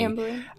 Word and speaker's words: Amblin: I think Gremlins 1 was the first film produced by Amblin. Is that how you say it? Spielberg's production company. – Amblin.